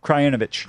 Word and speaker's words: Krajinovic: Krajinovic.